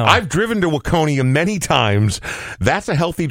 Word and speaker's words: Waconia: Waconia is how you pronounce it, to see a Suburbs show.